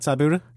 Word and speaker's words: Tybura: Are you all right with that, Tybura? – That's fine. – You cool with that? – Let's roll with that, yeah.